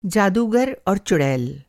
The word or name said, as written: चुड़ैल